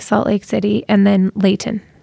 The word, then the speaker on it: Layton: You pronounced Layton correctly. – That's good. – You can tell you're from California. I like that. It's not Layton. – My kids. – It's Layton.